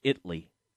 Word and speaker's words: Italy: Oh, don't get – Tom will get mad if we say it wrong. Remember, it's Italy.